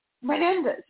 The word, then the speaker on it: Menendez: Menendez of New Jersey.